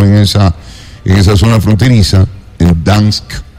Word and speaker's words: Donetsk: No se dice Dansk, sino Donetsk.